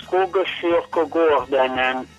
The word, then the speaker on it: Skogskyrkogården: Sono tre parole, Skogskyrkogården, vuol dire il cimitero nel bosco.